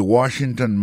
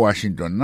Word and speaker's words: Washington: Si on a mis accent sur accent encore, on va voir que mot Washington, là, accent tombé sur premier syllabe, là.